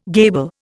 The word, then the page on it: gable: Indian